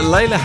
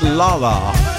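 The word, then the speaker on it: Lalah: And he's telling me that Layla Hathaway's name should be pronounced Lala. Lala Hathaway. But there's an H at the end.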